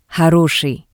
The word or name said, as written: Horoshii